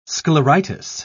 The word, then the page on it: scleritis: British English